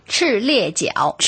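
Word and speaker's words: 赤鱲角: 赤列角。 – 赤列角。 – 赤列角，咁呢个角咧，同我哋个脚咧，发音一样的。 – 对，我觉得最主要是赤列角的列字，因为通常这个字就是比较难读一点，对不对？ – 对，这个列呢，跟那个火车一列火车的列发音一样。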